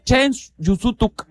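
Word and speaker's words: chéen jun súutuk: Dice, lo estás pronunciando mal, me dice por acá. Ya me están regañando. ¿Está bien, maestrito, maestrito o maestrita Adi? ¿Qué tal, Adi? Dice, solo una vez. Significa solo una vez. ¿Qué es? Chen Junsutuk. Chen Junsutuk. A ver, Adi, Adi, muchas gracias. Adi, Adi, mándame ya cómo se dice para que yo aprenda. Chen Yusutuk.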